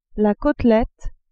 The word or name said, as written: cotelette